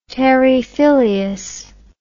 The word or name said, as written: terrae filius